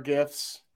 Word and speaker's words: GIF: So as we're going back and forth with our gifts, I'm a firm believer it's pronounced GIF, not "jiff."